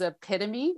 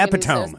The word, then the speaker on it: epitome: Like the example I like to give is epitome, which my son, you know, was reading. – You mean epitome, says, yeah.